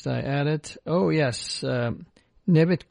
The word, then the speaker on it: Nevit: Nevit is spelled N-E-V-I-T. Kodallı is K-O-D-A-L-L-I.